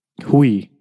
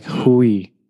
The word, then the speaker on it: Huy: But my name is spelled H-U-Y and it's pronounced Hui. So it's like Hui. Mm-hmm.